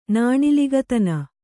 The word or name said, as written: ನಾಣಿಲಿಗತನ